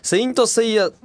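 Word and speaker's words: 聖闘士星矢: 星座の話といえばそうだね、セイントセイヤと読むよ。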